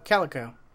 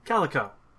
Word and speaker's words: Calico: Google X, and how do you say this? Caliso? – Oh, Calico. – Calico. Is under Alphabet, as well as, you know, Google itself.